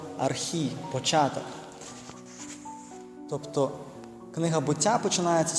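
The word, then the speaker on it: «архі» (ἀρχή): І те, що по-єврейськи «решіт» – це на грецькій мові є «архі», або якщо його по-інакше вимовляють «архе», та?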